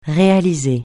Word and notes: réaliser: francais

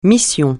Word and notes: mission: francais

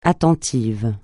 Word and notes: attentif: francais

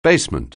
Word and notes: basement: anglais

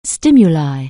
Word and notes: stimulus: anglais

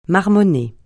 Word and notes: marmonner: francais

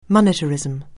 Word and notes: monetarism: anglais

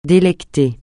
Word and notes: délecter: français

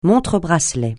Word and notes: montre-bracelet: francais

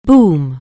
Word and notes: boom: anglais

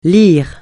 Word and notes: lyre: francais

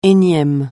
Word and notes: énième: français

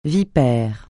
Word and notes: vipère: francais

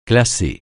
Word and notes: classer: francais

